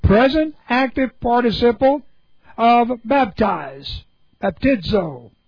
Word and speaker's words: baptizo: Okay, B-A-P-T-I-Z, long O, baptizo.